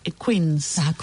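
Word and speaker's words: quince: Y se escribe, si uno lo leyera en español, se escribe igual que la palabra 15, que el número 15. – Sí, es verdad. – Pero se dice Queens.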